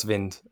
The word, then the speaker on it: Wind: Well, I believe it says Wind, but you can never really know with these UEFA pronunciation guides. They can tend to be a little bit dodgy, but we'll go with Wind. – I think we'll go with Jonas Vind.